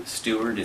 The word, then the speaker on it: Steward: Seth is S-E-T-H. Mm-hmm. Stewart is S-T-E-W-A-R-D.